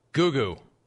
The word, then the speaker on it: Goo Goo: Google's Dolls. – They're not the Google's Dolls. – Google's Dolls. – Goo Goo. I mean, yeah, it's a stupid name for a band. It really is. – Goo Goo Dolls.